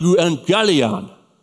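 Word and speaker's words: euangelion (εὐαγγέλιον): The word gospel, the Greek word, is pronounced euangelion.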